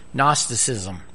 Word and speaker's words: Gnosticism: G is silent, so Gnosticism.